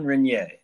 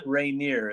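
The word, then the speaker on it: Reynier: Jean Renier, not Rainier, as I call him. – Yeah. You know, well, I hear you. You're in English pronunciation and yes. The French would say Renier.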